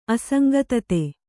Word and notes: ಅಸಂಗತತೆ: asaŋgatate

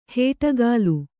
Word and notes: ಹೇಟಗಾಲು: hēṭagālu

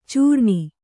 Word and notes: ಚೂರ್ಣಿ: cūrṇi; Kannada

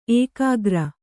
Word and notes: ಏಕಾಗ್ರ: ēkāgra

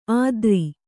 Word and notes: ಅದ್ರಿ: Kannada

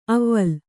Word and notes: ಅವ್ವಲ್: avval

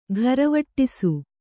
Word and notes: ಘರವಟ್ಟಿಸು: gharavaṭṭisu

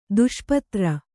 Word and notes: ದುಷ್ಪತ್ರ: kannada

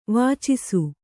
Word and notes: ವಾಚಿಸು: Kannada

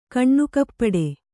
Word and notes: ಕಣ್ಣುಕಪ್ಪಡಿ: kannada

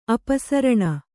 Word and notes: ಅಪಸರಣ: apasaraṇa

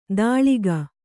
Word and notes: ದಾಳಿಗ: dāḷiga

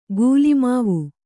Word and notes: ಗುಲಿಮಾವು: Kannada

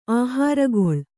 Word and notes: ಆಹಾರಗೊಳ್: Kannada

